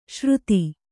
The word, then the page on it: ಶ್ರುತಿ: śruti